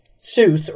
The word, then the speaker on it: Seuss: Okay, so it's Zeuss. – Is Zeuss. Correct. It's his mom's maiden name, Seuss or Zeuss, if you will. Uh-huh. And it was supposed to rhyme with voice.